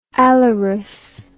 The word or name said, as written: a la russe